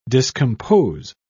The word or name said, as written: discompose